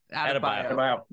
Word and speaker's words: Adebayo: It's Caleb Martin, it's like everybody Bam Amadeo, Adebayo. You can never pronounce that one. – I can never pronounce Adebayo. – Adebayo. – There you go.